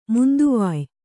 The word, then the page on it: ಮಂತವ್ಯ: Kannada